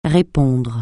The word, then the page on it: répondre: francais